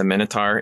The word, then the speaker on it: Minotaur: And like there's Gorgons and Medusa is Minotaur. How do I say it? – The Minotaur.